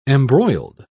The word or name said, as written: embroiled